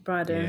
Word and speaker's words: Brydon: How do you pronounce his Brydon. – Name? Brydon. Yeah.